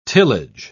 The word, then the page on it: tillage: British English